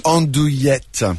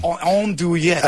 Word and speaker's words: Andouillette: Andouillette. – Andouillette.